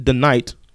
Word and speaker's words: DeKnight: In a new interview with Collider, Pacific Rim Uprising director Stephen DeKnight, did I say that right? – Yes, sir.